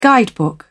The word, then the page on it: guidebook: British English